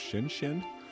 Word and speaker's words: Shn Shn: This is, I believe it's pronounced Shin Shin. It's spelled S-H-N-S-H-N. And this is a song called Melt.